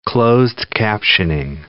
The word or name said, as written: closed-captioning